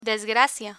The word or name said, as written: desgracia